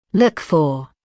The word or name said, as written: look for